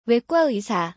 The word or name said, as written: 외과의사